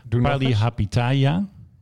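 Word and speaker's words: Palihapitiya: Hij heet, ik spreek zijn naam natuurlijk verkeerd uit. Hij heet Shamath Palipataya. Shamath Palihapitaya.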